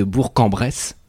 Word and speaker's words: Bourg-en-Bresse: Trouvez-nous les deux autres personnes qui nous écoutent de Bourg-en-Bresse.